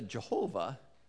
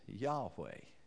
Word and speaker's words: Yahweh: It isn't Jehovah, it's Yahweh. The second thing we know is that Yahweh is probably pronounced Yahweh. We get partway there in a word that you're all very familiar with, which is Hallelujah, which is praise to Yah.